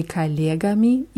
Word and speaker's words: коллегами: Да, bei коллегами ist die Betonung auf je. Вчера я долго разговаривал по телефону с моими коллегами из Америки.